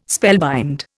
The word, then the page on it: spellbind: Indian